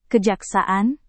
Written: kejaksaan

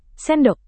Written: sendok

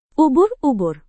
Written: ubur-ubur

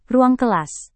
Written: ruang kelas

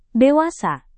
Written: dewasa

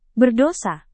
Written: berdosa

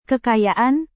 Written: kekayaan

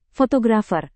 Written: fotografer

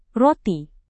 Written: roti

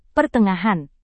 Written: pertengahan

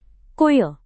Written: kuil

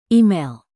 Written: email

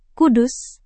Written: kudus

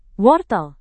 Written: wortel

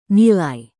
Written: nilai

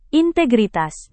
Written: integritas

